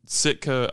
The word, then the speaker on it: Sitka: Now, you mentioned Sitka, Alaska. Am I pronouncing that correctly? You are. Yeah. Okay, good.